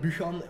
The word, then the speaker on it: Buchan: Dus ik dacht dan van, heb ik een coole naam in mij en Buchan op zich klinkt wel cool, een beetje mysterieus, je weet niet echt waar het vandaan komt. Ja. Je kan het in zo goed als elke taal uitspreken.